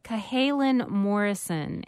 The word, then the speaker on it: Cahalan Morrison: And I think I mispronounced. It's Cahalan Morrison and Eli West. – Yeah, that right? – I believe that is it, correct.